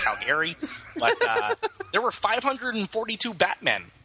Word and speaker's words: Calgary: You got to pronounce it right. It's Calgary. Come on.